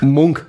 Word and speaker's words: Munch: Mhm, wie Edward Munk. Munk, so spricht man ihn aus.